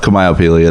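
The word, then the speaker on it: Kamaiopili: Kamayo, or Mitch Kamayo, look up Kamayo Peely.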